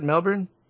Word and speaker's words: Melbourne: And did I say that right? Melbourne? – Yeah, that was pretty good for an American.